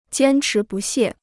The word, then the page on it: 坚持不懈: jiān chí bù xiè